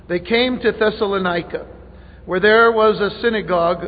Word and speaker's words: Thessalonica: It's Amphipolis, Apollonia, and Thessalonica.